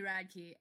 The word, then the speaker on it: Radke: How did we get from Elijah Benjamin, not Elijah, oh my god, Alec Benjamin to Ronnie Radek? – It's Ronnie Radke.